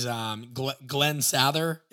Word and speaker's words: Sather: And I think the guy's name was Glenn Sather.